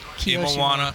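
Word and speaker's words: Imawano: Now, how's it pronounced? – Imawana. Kiyoshiro.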